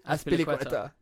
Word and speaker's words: Azpilicueta: Azpilicueta.